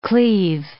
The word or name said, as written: cleave